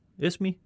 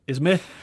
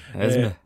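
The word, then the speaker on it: Esmé: Nu prøver jeg bare at udtale det forkert med vilje, fordi det øh, irriterer mig lidt. Esmi. Esme. – Esme.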